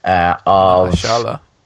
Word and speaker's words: Mahershala: And then comes along Madden Longshot that casts, and I'll butcher his first name, Mahershala Ali. – Mahershala. – Oh, yeah. Mahershala, yeah.